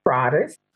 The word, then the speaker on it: Prades: The last name is Prades.